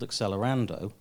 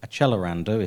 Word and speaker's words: Accelerando: collectively titled Accelerando, or Accelerando if you pronounce it in proper Italian, although nobody does, and also a fix-up novel derived from the same source.